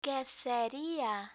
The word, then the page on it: queseria: Spanish